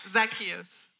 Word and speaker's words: Zacchaeus: It was Zacchaeus, sorry. – ¿Cómo te dije? – He said it right, Zacchaeus.